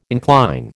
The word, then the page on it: incline: American English